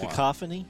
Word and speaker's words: cacophony: and just the how do you say it? C-A-C-O-P-H-O-N-Y. – Cacophony? – Cacophony. Thank you.